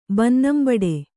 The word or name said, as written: ಬನ್ನಂಬಡು